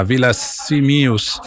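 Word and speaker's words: Villasimius: E ho fatto eventi in tutta la Sardegna, quindi ad esempio dall'Estasis di Santa Teresa di Gallura fino a Villa Simius.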